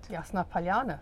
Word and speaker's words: Yasnaya Polyana: But um, so I went to Yasnaya Polyana, which I always pronounce incorrectly. How, how should I say it? Yasnaya Polyana. – Very there, good.